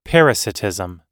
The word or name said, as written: parasitism